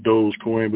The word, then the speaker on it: Dogecoin: Now, am I saying that right? Dogecoin? – Yeah, it's pronounced Dogecoin, but people call it Doggy Coin.